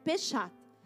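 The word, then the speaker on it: Peshat: Quando você pega uma história da Bíblia, existe um primeiro nível chamado Peixato. Pechat. E essa, mas não é Peixato de Páscoa não, tá? Deixa eu ver se eu estou falando certo. É Pechat.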